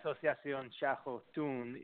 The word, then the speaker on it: Asociación Chajotun: And then keep on going, because I don't know how to pronounce Asociación and then the rest of that. Okay, Asociación Chajotun is indigenous.